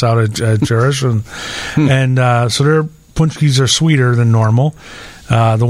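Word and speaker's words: pączki: And Jerish has some great Punchkis, and I asked them how to pronounce it. It's Punchkis. – Punchkis or Punchkis? Somewhere in between there, I think.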